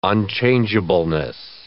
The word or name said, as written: unchangeableness